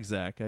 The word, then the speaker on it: Zach: Yeah, I do like Zach.